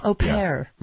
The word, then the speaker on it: au pair: Au pair.